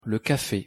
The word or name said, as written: le café